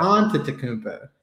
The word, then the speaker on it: Antetokounmpo: Careful, you better say it right. There's people out there that are accountable. – Yeah. It's and just Giannis Antetokounmpo or something like that. – Antetokounmpo. Yeah.